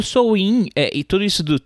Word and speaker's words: Samhain: Os praticantes aqui no Brasil, os wicanos, falam Sowin. A pronúncia celta é Sowin, tá? O, o pessoal aqui no Brasil fala Samain,